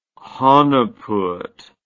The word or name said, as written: hanepoot